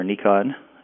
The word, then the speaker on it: Nikon: I use, as far as on the photography side, I use Nikon, as it's actually pronounced, gear.